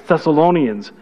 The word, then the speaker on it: Thessalonians: And so because of that, speaking specifically of the Thessalonians, I was corrected as to how to pronounce, I would say Thessalonians. And so my son came up to me and goes, every time I hear you say it, I just twitch. It's Thessalonians.